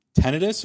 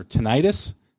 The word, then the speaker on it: tinnitus: and I do have a little bit of tinnitus or tinnitus, depending on who you talk tinnitus. – To. Yeah, who we talk to is how they pronounce it,